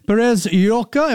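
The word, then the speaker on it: Pérez-Llorca: Pablo Ontoria is joining me, resident partner at Singapore office for the law firm Perez Yorka. Am I pronouncing that correctly? Close? – It's correct.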